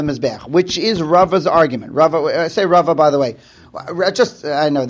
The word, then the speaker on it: Rava: Rabba, when pronounced is with a hay at the end, I say bays. And when it's Rava, I say with a vav. Okay, anyway. You know, pronounced like a vav.